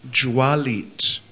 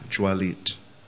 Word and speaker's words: Jvalit: D-H long U, M long A, Y-I-T-A. Dhumaita. Did you get that? Second one, blazing, Jwalit, J-V-A-L-I-T, short I, Jwalit.